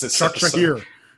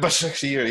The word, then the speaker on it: Başakşehir: United winning 5-0 against Leipzig and then losing to Istanbul Başakşehir here. Oh, I'm flexing my vocal cords. – Başakşehir. – Yeah, Başakşehir.